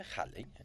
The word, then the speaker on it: Halley: Se dice Halley, como el cometa, ¿no lo conoces?